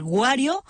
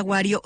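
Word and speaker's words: Wario: Y a mí me pueden seguir en X y en Facebook como Mafalda Guario. Y en Instagram, Mafalda Guario G, con una G de García al final. Recuerden que mi apellido se escribe con W, una sola R, sin U, con A directamente.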